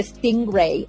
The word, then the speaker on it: Stingray: Sobre todo el Stingray.